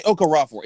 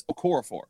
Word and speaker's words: Okorafor: You say Okorafor. It's Okorafor. You can't prove that. yes, I can. It's literally on the pronunciation sheet that the Steelers give.